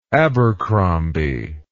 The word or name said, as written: abercrombie